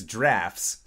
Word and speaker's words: draught: and even though it is D-R-A-U-G-H-T, it's drafts.